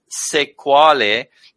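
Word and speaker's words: Sexwale: So his first name is Tokyo, as in the capital of Japan, and his surname is pronounced Sekwale, but it's actually spelt almost like sex whale.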